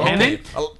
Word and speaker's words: Tenet: Tenet. Tenet. T-E-N-E-T. So it's spelled the same forwards and backwards. – David? – As in David Tennant.